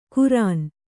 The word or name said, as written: ಖುರಾನ್